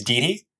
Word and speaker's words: Dhiti: For other main characters, in Mio's friend group is Didi. I think that's how you say her name. D-H-I-T-I.